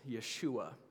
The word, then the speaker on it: Yeshua: We are talking about the Hebrew pronunciation of the name of Jesus, Yeshua.